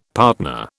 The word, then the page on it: partner: British English